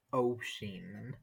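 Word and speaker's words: Oisín: And if it does have the accent, it's pronounced osheen. So the S-I-N is sheen. Usheen or osheen.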